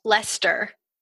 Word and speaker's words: Leicester: It's spelled like Leicester, but you pronounce it like Lester.